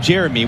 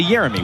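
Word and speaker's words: Yeremy: Because Jeremy Pino, that's how he says his name, Jeremy, with a hard J sound. And yet, any game you watch in the Premier League, any commentator is going to say Jeremy.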